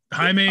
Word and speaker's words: Jaime: He's always been it could have been Jamie Reyes, but he's always been Hispanic kid. – Okay. – It's Jaime. – Yeah. Jaime. Jaime is the correct pronunciation.